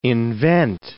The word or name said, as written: invent